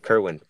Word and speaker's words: Kerwin: Kerwin.